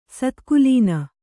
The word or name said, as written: ಸತ್ಕುಲೀನ